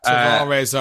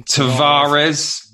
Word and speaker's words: Tavares: Probably absolutely butchered that name. – Tavares, I reckon. – Tavares, yeah. Like that.